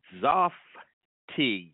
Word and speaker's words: zaftig: Now, today's word of the day is Zaftig, Zaftig, Z-A-F-T-I-G, is pronounced Zaftig.